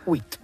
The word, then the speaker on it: huit: Huit.